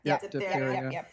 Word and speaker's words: diphtheria: Yeah, diphtheria.